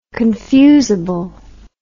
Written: confusably

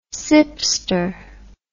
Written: sipster

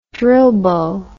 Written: drill bow

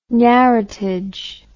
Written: narratage

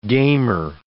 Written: gamer

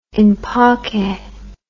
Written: in pace

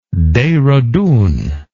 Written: dehradun

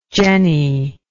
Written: jennie

